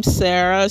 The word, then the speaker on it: Sarah: That's my name, Sarah, spelled with an H underscore and the word journalist.